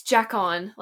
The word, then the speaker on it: Jacon: It's Jacon, like Jack and then on.